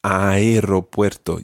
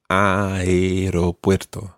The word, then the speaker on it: aeropuerto: Voy al aeropuerto. – Yeah, almost right. That aeropuerto, you pronounce the A and the E. The aeropuerto. – Aeropuerto.